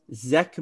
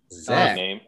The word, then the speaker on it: Zech: With their fourth pick, the Eagles picked corner Zech McPherson. – Zach. – Z-E-C-H. That's what I'm pronouncing it as.